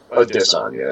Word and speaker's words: Adesanya: Like, nobody's buying an individual, like, Israel Adesanya or Adonijay or however you want to call him. Adesanya is actually how you pronounce it.